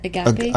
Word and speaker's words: agape: And agape... Is that how you say it? Agape? – Agape, yeah.